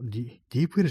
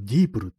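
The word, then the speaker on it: DeepL: 割にね、このディープ L じゃなくて、今度はディープルって読むらしいですね。これ、ちょ衝撃的だったんですけども、ディープ L じゃないんだっていうね、ディープル、ね。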